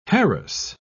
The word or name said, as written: harass